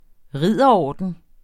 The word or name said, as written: ridderorden